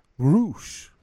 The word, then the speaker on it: Rush: Because it's not because they say Roosh. It's because they roll the R. Roosh, right? – Yeah.